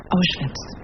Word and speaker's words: Auschwitz: Auschwitz.